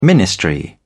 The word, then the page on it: ministry: British English